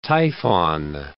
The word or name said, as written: Typhon